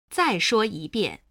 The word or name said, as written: zai shuo yibian